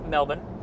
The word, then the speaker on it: Melbourne: So we did a show with SEMA as well there in Melbourne, mm-hmm. or Melbourne. To say it properly. – Sure. – As the Aussies would.